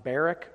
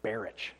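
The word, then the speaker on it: Barach: But instead, he was replaced with John Barrick, or I guess, having done some further research in the week past, Doug Wilson pronounces it Barrich, so we'll, at least in terms of pronunciation, we'll use Wilson as the gold standard.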